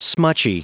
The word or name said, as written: smutchy